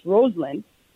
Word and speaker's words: Rosalind: So my name, like, it looks like a rose in the line. It actually looks like it's actually pronounced Rosalind.